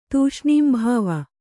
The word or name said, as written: ತೂಷ್ಣೀಂಭಾವ